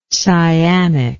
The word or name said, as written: cyanic